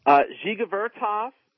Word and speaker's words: Dziga Vertov: Dziga Vertov, who did a series of newsreels called Kino Pravda, and that means film truth.